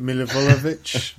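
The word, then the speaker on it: Milivojević: I mean, like, everyone almost can't say his name. Milivojevic. Yeah, you got it.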